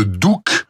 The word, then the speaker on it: Douch: Il était plus connu sous le nom de Douk. En fait, ça s'écrit D-O-U-C-H, mais on prononce D-O-U-K.